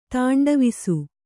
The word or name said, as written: ತಾಂಡವಿಸು